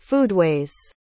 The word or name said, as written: foodways